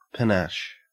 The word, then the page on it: panache: Australian English